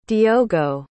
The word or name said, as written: diogo